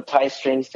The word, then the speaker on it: TY Stringz: Yeah, so I, I know people are like, oh, like, you know, Ty Stringz, T-Y, but it's T-Y Stringz.